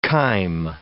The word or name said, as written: chyme